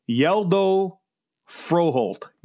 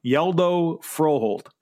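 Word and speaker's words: Hjalte Froholdt: Here's how you say it. I have the pronunciation. It's Yeldo Froholt. Yeldo Froholt. That's from Denmark. That's how you pronounce it.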